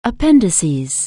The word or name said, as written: appendix